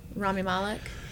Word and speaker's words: Rami Malek: Rami Malek? – Yeah, that's how you say it.